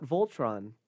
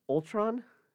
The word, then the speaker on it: Ultron: Not Voltron. That's... – What's his name? – Ultron? – Why is it spelled with a V? – They don't... It's not... It's Age of Ultron, not...